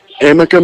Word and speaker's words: Amica: Did I say that right? Amica? – Amica Mutual Pavilion, yes.